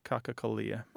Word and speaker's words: cacocallia: Is it C-A-C-O-C-A-L-L-I-A, cacacalia.